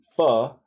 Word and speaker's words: Pho: She also introduced me to Pho. I think I said it right. Is that right? – You did.